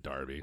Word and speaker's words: Derby: It's spelled Derby, right. but they pronounce it Derby.